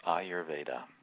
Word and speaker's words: Ayurveda: And the correct pronunciation is Ayurveda. – Ayurveda.